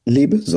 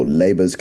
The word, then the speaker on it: Leber's: So Leber's or Leber's congenital amaurosis, depending on how you want to pronounce it,